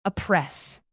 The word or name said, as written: oppress